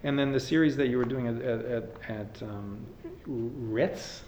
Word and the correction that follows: Rétes: is that how you pronounce it? – Rétes. – Oh, okay. I'm sorry. Thank you. – Rétes, it's a Hungarian pastry.